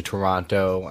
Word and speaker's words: Toronto: You say Toronto like an American. – Toronto. – You do. You say... – Anyways. – Americans say Toronto weird. Have you noticed that? They put a lot of emphasis on the second T.